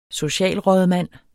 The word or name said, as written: socialrådmand